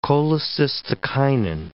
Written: cholecystokinin

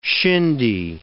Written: shindy

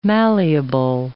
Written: malleable

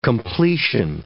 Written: completion

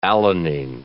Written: alanine

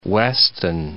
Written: Weston

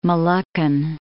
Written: Moluccan

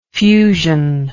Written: fusional

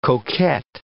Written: coquet

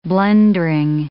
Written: blundering

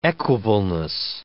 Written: equableness

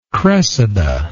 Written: cressida

